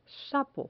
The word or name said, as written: sapo